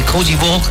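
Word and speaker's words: Côte d'Ivoire: Ya hay alineaciones por parte de Alemania ante Cozy Ball, así se pronuncia en François.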